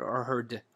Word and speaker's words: O'Hearn: That's how you pronounce it? O'Hearn. Okay. With an N.